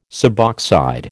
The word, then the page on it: suboxide: American English